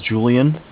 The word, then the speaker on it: Julian: Yeah, and my name is Julian. It's J-U-L-I-A-N.